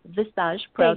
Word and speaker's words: Visage: And so today, you know, fast forward to today, my company, Visage Pro, I know you tried to pronounce it, it's actually called Visage Pro. – Thank you. I will practice. Oh, it's French.